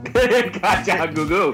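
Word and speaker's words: Kajagoogoo: Well, what is the proper pronunciation of the band's name? Is it Kaja? – Kaja Gugu. – Kaja Goo Goo. – Is that really it? Kaja Goo Goo to you too, sir.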